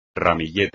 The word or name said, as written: ramillete